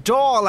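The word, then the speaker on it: Dáil: Another worrying development is the prospect of, if I'm pronouncing this correctly, a hung Dale. Who is Dale? What on earth did he do to deserve this? – Well, it's pronounced Dahl, actually, and that's a reference to the parties not being able to form a government easily.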